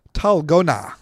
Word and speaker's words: Dalgona: Talgona. – Talgona. – Talgona. – The D is pronounced like a T.